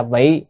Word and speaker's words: vị: Vị độ quần sanh, chữ vị ở đây có hai âm, một là vi hay là vị, ở đây phải đọc là vị.